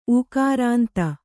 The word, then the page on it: ಊಕಾರಾಂತ: ūkārānta